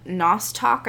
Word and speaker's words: nostoc: And that would be called nostoc, I believe is how it's pronounced.